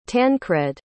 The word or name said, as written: tancred